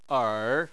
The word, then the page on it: er: 3